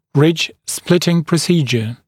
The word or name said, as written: ridge-splitting procedure